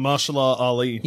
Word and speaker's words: Mahershala Ali: It's a movie, yeah, with I don't know how to pronounce his name, but I love the actor Mahershala Ali or something. – Mahershala Ali. – Yeah, Mahershala Ali.